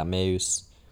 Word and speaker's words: Meuse: how do you say it, Meuse Valley? – Yeah, Meuse. – Yeah, Meuse Valley in what was then the Spanish Netherlands, which is actually present day Belgium.